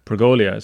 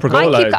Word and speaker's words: pergola: There's no I in Pergola. – Oh, I know. I said it wrong. See, I didn't even know how to pronounce it, but I'm getting one. – What was I saying? – Pergolia. It's just Pergola.